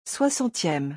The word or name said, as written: 60eme